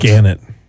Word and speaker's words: Gannett: do you pronounce it Gannett or Gannett? Gannett.